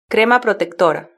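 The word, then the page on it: crema protectora: European Spanish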